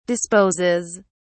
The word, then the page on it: disposes: British English